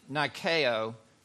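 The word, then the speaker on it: Nikeo (νικάω): I'm going to pronounce it Nikeo. You hear Nike in it.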